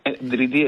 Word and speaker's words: Дриди: Поне така твърди и Хеди Дриди. Дриди е ударението.